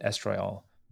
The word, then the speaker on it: estriol: And it has four components: alpha-fetal protein, beta-HCG, unconjugated estriol, and is that how we pronounce estriol, right? – Yeah.